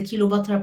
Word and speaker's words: Kilobatra: In Death More Radiant, the Kilobatra part is how Egyptians pronounce Cleopatra.